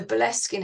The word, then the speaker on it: Boleskine: so Boleskin House, it's spelled Boleskine, and it's run by this charitable trust now, this man that's trying to rebuild it.